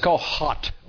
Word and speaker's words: Kohath: I pronounce it as Kohath or Kohath. K-O-H-A-T-H or just with a T.